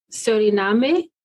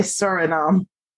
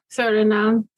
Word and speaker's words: Suriname: And I want to say this correctly, is Suriname? Or am I pronouncing it? That's actually how you would say it in Spanish or Portuguese. But in English, we would say Suriname. – Suriname.